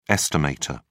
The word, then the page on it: estimator: British English